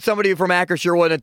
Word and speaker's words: Acrisure: It's Acrisure. – It is not Acrisure. I heard an ad yesterday that was basically the company itself saying Acrisure. It's not Acrisure.